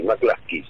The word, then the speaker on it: McCluskey: Donald Clifton McCluskey, McCluskey se pronuncia, McCluskey.